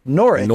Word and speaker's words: Norwich: It's pronounced Norwich. – Norwich. – The W is silent, just as in Greenwich Village.